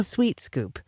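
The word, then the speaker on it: sWheat Scoop: Sweet, but it's spelled S-Wheat. Scoop, fast clumping all-natural cat litter. $20 for a bag. – I think that's pretty cute, right? – Yeah. – Sweet scoop.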